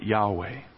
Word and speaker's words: Yahweh: and we would pronounce it Yahweh.